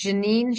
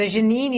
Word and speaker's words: Jeanine: So it's Jeanine Sciacca, and I'll spell that. So Jeanine is Jeanine Sciacca, Sciacca.